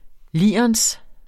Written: lirens

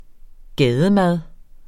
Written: gademad

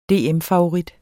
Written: DM

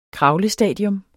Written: kravlestadier

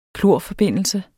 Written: klorforbindelse

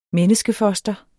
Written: menneskefoster

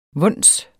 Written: vons

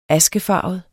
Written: askefarvet

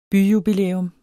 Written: byjubilæum

